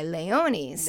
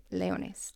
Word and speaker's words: Leones: Jose Ramirez, super excited to say, started last week with my Leones. – Nice. – And he, Leones, I said that wrong the first time. All right, Leones. That's much better.